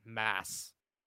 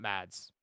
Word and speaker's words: Mads: Fucking, I mean, it's Mads Mikkelsen. That's how you actually pronounce it. Mass. Not Mads.